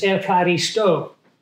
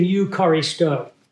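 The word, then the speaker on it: ekaristo (ευχαριστώ): It's very interesting because the Greek word for giving thanks is ekaristo. Or if you use Bible school pronunciation, eucharisto. I try to use the modern Greek pronunciation because that's the language that they speak.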